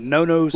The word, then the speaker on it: Nonno's: N-O-N-N-O. – Oh, okay. I thought you said nose. – No. Nano's is three N's in there. N-O-N-N-O-S.